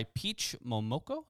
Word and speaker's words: Peach Momoko: The series has spawned various comic book spinoffs, which I'm highly interested in, one of which was written by Peach Momoko. I don't know if I'm pronouncing that correctly. – Looks right.